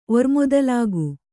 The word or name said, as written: ಒರ್ಮೊದಲಾಗು